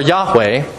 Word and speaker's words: Yahweh: YHWH, which can be pronounced Jehovah or Yahweh.